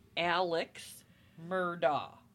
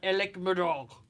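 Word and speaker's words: Alex Murdaugh: His name is not Alex, apparently. It's Alec. – But it is. It's Alex Murdoch. Not Alec Murdoch, whatever they're saying.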